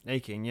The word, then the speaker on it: Aching: Tiffany, I'm never sure how to pronounce her surname. Aching? – Aching, yeah.